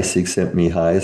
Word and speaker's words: Csikszentmihalyi: And again, to quote the famous Hungarian psychologist whose name is Mihaly Csikszentmihalyi, some of your, it isn't spelled like that because there's all kinds of L's in there that apparently are not pronounced in Hungarian.